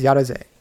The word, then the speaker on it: Yaroze: How do you say that? I think it's Yaroze. – Yaroze. – So, yeah, because yeah, it's it's Yado is like let's do it and Z is kind of like the exc- exclamation ending.